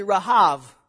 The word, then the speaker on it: Rahav: So this is actually Rahav, which is not the name of Rahab. She would take great offense because you just mispronounced her name, okay, which is more like Rahav, okay?